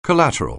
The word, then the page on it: collateral: anglais